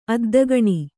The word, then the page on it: ಅದ್ದಗಾಣಿ: addagāṇi